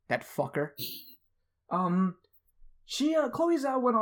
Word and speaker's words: Chloe Zhao: So you know Chloe Zhao? Am I pronouncing that right? – Yes, I believe so.